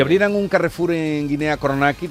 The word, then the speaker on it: Conakry: Conakry, Conakry.